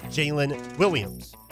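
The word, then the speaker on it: Jaylin Williams: First round pick they got is spelled J-A-L-E-N. Second round pick is J-A-Y-L-I-N, but pros pronounced Jalen Williams.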